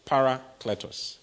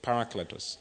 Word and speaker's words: parakletos: Now, the word comforter is the Greek word parakletos. Okay? Parakletos. It's spelled P-A-R-A-K-L-E-T-O-S.